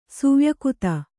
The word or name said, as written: ಸುವ್ಯಕುತ